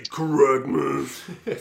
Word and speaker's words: CORRECTMAS: So you said it wrong. It's actually CORRECTMAS.